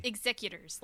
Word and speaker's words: Executors: We have Harden Hightown was released, and it, you know, we got more references to the Exequitors. Executors, how do we say it? – Executors, like business executors.